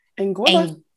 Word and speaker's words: Anguilla: They said I do on May 28th in the Eastern Caribbean island of Anguilla.